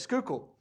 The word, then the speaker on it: Schuylkill: Schuylkill, I think, is how you pronounce it. What was it? Schuylkill. There it is, Schuylkill.